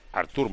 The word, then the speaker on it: Arthur: ¿te gusta Arthur o Artur? O Artur, no sé todavía cómo se dice. – Artur más.